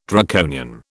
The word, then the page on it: Draconian: British English